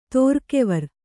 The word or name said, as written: ತೋರ್ಕೆವರ್